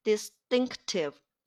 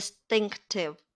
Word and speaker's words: distinctive: 在这里发音的时候注意，dist 后面这个 t 被前面。浊化了啊，后面这个清音 t 被前面的 s 给浊化了，发生了浊化现象，因此它变成 distinctive，轻声 t，读音发生变化，变成 t t distinctive。